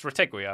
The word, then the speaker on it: Retegui: Well, it's technically a French surname, Retegui, right? It's not Italian. It's Retegui, I would say.